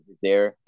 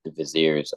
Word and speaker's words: vizier: vizier, the vizier, the vizier. I always mispronounce that.